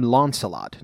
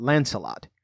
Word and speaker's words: Launcelot: Now, the first thing you might notice is that I'm calling him Launcelot, not Lancelot.